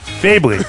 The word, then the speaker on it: Bayley: Bayley. – Bayley. – Bayley. – It's like, it's Bailey with an I. – Bailey? – Bailey. – Bailey. – Bailey. – It's still pronounced Bailey, but it's...